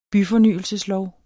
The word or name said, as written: byfornyelseslov